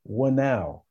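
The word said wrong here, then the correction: Whānau: Is it Wanau or is it something else? – So it's pronounced Faunau. – Faunau.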